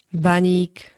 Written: baník

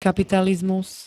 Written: kapitalizmus

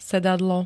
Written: sedadlo

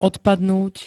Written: odpadnúť

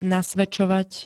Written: nasvedčovať

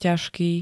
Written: ťažký

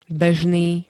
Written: bežný

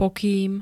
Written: pokým

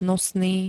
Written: nosný

